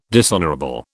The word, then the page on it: dishonourable: British English